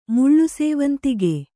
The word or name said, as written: ಮುಳ್ಳುಸೇವಂತಿಗೆ